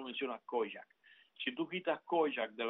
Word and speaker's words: Kojak: Kojak se escribe K-O-J-A-K, Kojak.